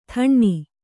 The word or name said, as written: ಥಣ್ಣಿ